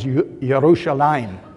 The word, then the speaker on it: Yerushalayim: Not to get too much into the Hebrew, but in modern Hebrew today, the name of that town is Yerushalayim. And it's called Yerushalayim because they add a Hebrew letter at the end of the word called a yud, and it's pronounced like an I.